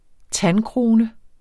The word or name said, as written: tandkrone